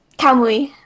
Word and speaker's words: Kamui: Kamui.